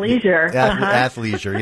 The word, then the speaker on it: athleisure: Athleisure, yeah, that's it, yeah, the ick is out of there. Just take the ick right out of that and then make things, yeah, athleisure, there we go.